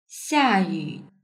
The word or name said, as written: xiayu